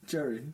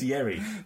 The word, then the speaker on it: Yerry: Jerry. – That's not his name. – His name is Jerry. – It's Yerry.